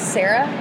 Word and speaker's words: Sarah: Yes, my name is Sarah. I spell it S-A-R-A-H.